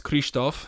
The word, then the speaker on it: Kristof: Fun fact, my name is pronounced Kristof, and it is Hungarian.